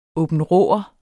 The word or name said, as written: aabenraaer